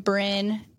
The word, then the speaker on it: Brin: It's just my first name Brin and then Amberlee.